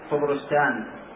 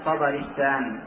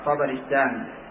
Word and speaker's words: طبرستان: طبرستان التي ينطقها بعضهم طبرستان أو طبرستان، وإنما هي طبرستان بفتح الطاء والباء وكسر الراء، طبرستان كما ضبطها صاحب معجم البلدان.